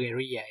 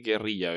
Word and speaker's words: guerrilla: Sí, pero tenemos que decir guerrilla, Beto, porque no acepto guerrilla que dicen los gringos. Que según ellos se pronuncia casi igual que gorila.